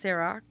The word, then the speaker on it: Ciroc: How do you pronounce it? – Chirac. It's French. – It's Ciroc. – Oh, is it Ciroc? It maybe it's Ciroc.